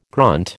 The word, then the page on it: grunt: American English